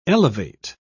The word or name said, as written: elevate